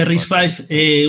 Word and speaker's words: RISC-V: RISC es una V, pero es un 5, ¿no? Se pronuncia RISC-V, una nueva arquitectura de ordenadores.